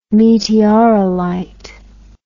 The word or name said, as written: meteorolite